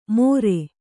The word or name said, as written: ಮೊರೆ